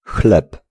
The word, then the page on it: pane: /ˈpa.ne/